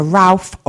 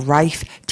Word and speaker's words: Ralph: Well, next time I'm going to be looking at the connection between Essex and composer Ralph, or Rafe, depending on how you pronounce it, Vaughan Williams.